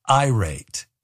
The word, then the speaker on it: irate: I pronounced it irat instead of irate.